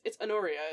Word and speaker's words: Honoria: Honoria is not the optimal Honoria. No, it's Honoria, as far as I know.